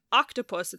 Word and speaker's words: octopus: But October, the emphasis is on the toe, and octopus, it's on the oc.